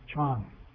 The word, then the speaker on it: Chan: And it's actually Japanese for the character that's in Chinese pronounced Chan.